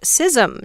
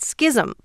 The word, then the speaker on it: schism: "The word is pronounced schism, not schism," pleads Jeannie Thomason in Half Moon Bay, California.